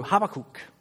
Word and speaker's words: Habakkuk: We see in verse 1 that this is the oracle of Habakkuk, or as my seminary professor pronounced it from the Hebrew, Habakkuk.